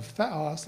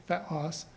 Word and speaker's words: theos: The very word theology in Greek is a combination of theos, that's how you pronounce it in Greek, theos, or and logos, meaning saying or word or discourse, depending on the context.